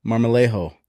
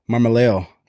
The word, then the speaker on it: Marmolejo: Today, I'm here with my good friend, Caleb Marmolejo. Marmolejo. – Nice pronunciation. – Yep. Did I say it right? – That's it. – All right. – Yeah, you could roll the R if you want, but I'll leave that up to you.